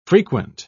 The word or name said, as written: frequent